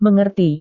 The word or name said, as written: mengerti